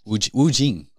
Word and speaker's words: Wu Jing: Or Ju Wing? – No, don't make fun of my Asian heritage. Ju Wing. – Wu Jing. I said it wrong twice.